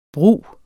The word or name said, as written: brug